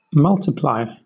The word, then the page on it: multiply: British English